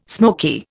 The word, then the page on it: smoky: Indian